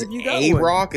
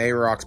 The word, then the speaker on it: Aroc: Their name is A-Rock, is how I would pronounce it. Spelled A-R-O-C.